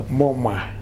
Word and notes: moma: Futuna

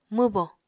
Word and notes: mwbo: Apatani

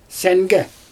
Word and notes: sAngE: Xyzyl

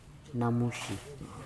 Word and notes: namuji: futuna aniwa

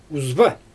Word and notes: uzba: Xyzyl

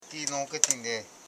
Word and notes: ngoketin: ngo.ke.tin

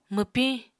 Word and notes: mwpiñ: Apatani

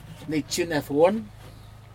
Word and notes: nijhenedwun: Aneityum

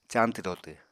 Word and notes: cañtw-dotw: apatani

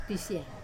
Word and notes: pisia: wamesa

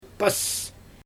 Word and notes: pas: Xyzyl

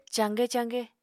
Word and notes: caaqe-caaqe: apatani

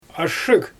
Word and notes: ashshyG: Xyzyl

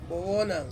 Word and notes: bowonang: Ansus

